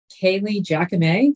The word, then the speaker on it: Kailey Jacomet: And I am here today with Kailey Jacomet.